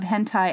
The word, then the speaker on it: hentai: Okay, number 10, the last one, and forgive me if I'm mispronouncing this, hentai? H-E-N-T-A-I. – Hentai, okay, yeah.